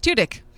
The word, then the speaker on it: Tudyk: Alan Tudyk? – Yes, Tudyk.